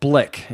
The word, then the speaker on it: Blyk: Well, there's a company over in the UK, and it's called Blick, is how it's pronounced, BLYK.